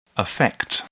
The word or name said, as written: affect